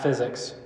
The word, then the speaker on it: physic: The book of physic, not physics, but physic, probably is how it's pronounced,